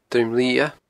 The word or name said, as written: Druim Liath